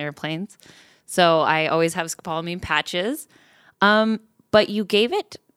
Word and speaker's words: scopolamine: Yeah, scopolamine.